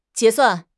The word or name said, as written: 结算